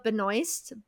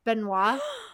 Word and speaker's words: Benoist: Sue Storm would be Melissa Benoist, Benoit. I think she pronounces it both ways.